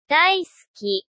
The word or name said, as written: daisuki